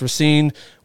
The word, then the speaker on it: Racine: Am I saying that right? Racine? – Yes, that's correct. – Yes, Racine, Wisconsin.